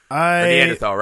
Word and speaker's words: Neanderthal: Neanderthal, right? – Where I'm like, I think you pronounced it right.